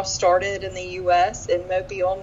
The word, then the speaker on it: Mobile: And it is pronounced Mobile, not mobile, like mobile phone.